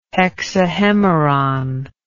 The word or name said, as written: hexahemeron